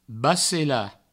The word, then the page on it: bacelar: bacela; Provençal